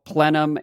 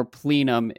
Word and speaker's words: plenum: As far as clarity is concerned, do we have clarity on how plenum and or plenum is actually pronounced, is correctly pronounced? I've heard it pronounced both ways. Do you have a preference? – I don't. I use it interchangeably.